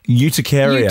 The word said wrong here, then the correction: urticaria: Uticaria. Aquagenic urticaria. But there's an R in there.